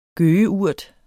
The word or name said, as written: gøgeurt